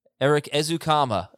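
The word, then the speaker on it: Erik Ezukanma: Eric Ezukama is how we pronounce it.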